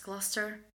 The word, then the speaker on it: Gloucester: Do you have any? Gloucester? – Yeah, that's right. It makes no sense to me that that's Gloucester.